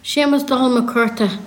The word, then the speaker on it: Seamus Dall Mac Cuarta: I know that one of the most famous that I've read about is the blind poet Seamus Dall Mac Cuarta. Hopefully I pronounced that correctly. Yeah. – Seamus Dall Mac Cuarta, James McCourt, and the Dall means blind.